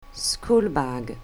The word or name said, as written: schoolbag